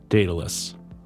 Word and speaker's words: Daedalus: Is it Daedalus or Daedalus? – Daedalus.